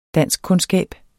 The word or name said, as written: danskkundskab